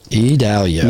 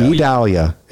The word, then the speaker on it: Idalia: And we want to naturally say Vidalia, so yes. Idalia. It's kind of like how we want to say it, because you just take the V off of our town and our inner areas. But no, that is not how it is phonetically put out for us.